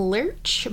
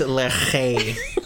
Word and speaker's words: Lerche: You're saying Lurch, but I always thought it was, like, Lerche. – It's Lerche, actually.